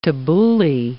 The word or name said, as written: tabouli